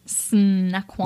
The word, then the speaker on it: Snoqualmie: It's SN. It's SN are the first two letters. – That's pronounced SN. – Snoqualmie. – Like snacks. – Yes, it is.